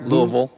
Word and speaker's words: Louisville: I think I said that right. – Louisville. – Louisville. – Louisville. – Okay, Louisville, Kentucky.